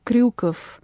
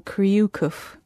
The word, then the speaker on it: Kryukov: I think probably one that's deceptively simple-looking when you see it written down, but it's Nikita Kryukov. Because you don't get that ryu sound in English, obviously, because we don't roll the R. So it ends up having to be an extra syllable, Kryukov. Tatiana Velasajar.